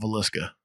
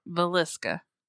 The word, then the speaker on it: Villisca: You put the emphasis in the wrong syllable. – On there for it, but got the emphasis on the wrong syllable. It happens. – Well, how do you say it again? Because you know my method. – I call it Villisca. – Villisca. – Yeah. That sounds right to me.